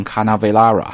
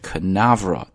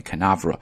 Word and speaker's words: Canaveral: 那么在读的时候一定要注意这个单词的动音，不要读成 Canaveral，那就对 Canaveral，重音在 na 上面 Canaveral。